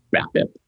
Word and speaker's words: BABIP: So if you go out and ball out, but you have a high, BABIP? Is that how you say it? – Batting average on ball and play. Yeah. – Yes. – BABIP. – Hey, that's how you word that. That's how you say that.